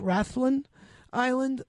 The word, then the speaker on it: Rathlin: And so also up in Northern Ireland, there's an island, I think it's pronounced Rathlin Island.